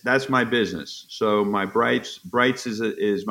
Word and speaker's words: Breitz: And so B-R-E-I-T-Z doesn't mean anything. – It stands for Brights. – Exactly. So it's pronounced that way. You know, some people say Breets. And so when I write it, I say pronounce Brights.